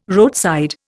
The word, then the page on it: roadside: Indian